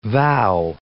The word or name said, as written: vow